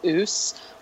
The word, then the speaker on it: Aass: Isn't this the Norwegian beer they're finally putting into the beer cart in Norway, and it's pronounced oos,